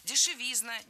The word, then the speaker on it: дешевизна: В этом слове, опять же, ударным должен быть суффикс «изн». Дешевизна, дешевизне. И, кстати, парой эти слова запомнить удобнее. Дешевизна, дороговизна, ударение однотипное.